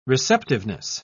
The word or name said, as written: receptiveness